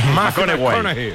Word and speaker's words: McConaughey: Hay que ir corrigiendo la pronunciación. – McConaughey. McConaughey. Bueno, pues el Mateo se...